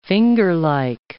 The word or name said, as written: fingerlike